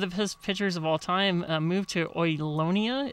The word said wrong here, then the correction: Olinda: Is that how you pronounce it? – Mm-hmm. – And then it's now incorporated into the city of Brea, and he moved. – Well, Olinda. Olinda. – Okay. Yeah, Olinda. Yeah.